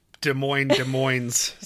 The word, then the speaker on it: Des Moines: To be fair, these are the people who pronounce Des Moines. So I think you're perfectly. – You only pronounce one of the S's.